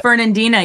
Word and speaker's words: Fernandina: Fernandina, yeah, there's Fernandina there's, Beach.